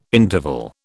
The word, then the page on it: interval: British English